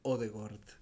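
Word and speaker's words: Ødegaard: Eh, ah, otra, otra aula grande, eh, la pronunciación correcta de los nombres noruegos con doble A, las doble A se pronuncian como O. Entonces el correcto sería Erling Braut Holland y eh, Martin Odegaard.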